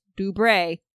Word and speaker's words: Dubray: The pronunciation in the notes was Dubray. Like a donkey bray.